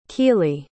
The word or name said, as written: keely